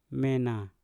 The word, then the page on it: mena: Mpur